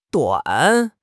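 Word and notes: duan3: tone 3

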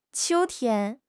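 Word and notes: qiu1 tian1: tone 1

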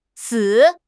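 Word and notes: si3: tone 3

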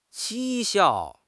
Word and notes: 讥笑: HSK6

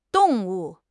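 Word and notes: dong4 wu4: tone 4

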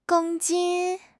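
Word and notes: gong1 jin1: tone 1

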